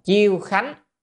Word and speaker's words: Chiêu Khánh: chữ này đọc là Chiêu Khánh, chùa Chiêu Khánh tại ở Hàng Châu, là hai cái chùa này, là tên hai chùa hai nơi.